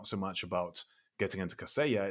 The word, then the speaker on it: Kaseya: The other big news story was, I'm not actually sure how to pronounce this company's name, Kaseya? – Kaseya.